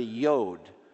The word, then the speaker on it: yod: It's just like a little apostrophe, and it's pronounced yeah, like a Y sound. So that's what a yod is.